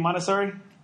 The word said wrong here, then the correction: Montesino: Montesino. – Okay, man, Montesino.